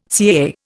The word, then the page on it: CAA: Indian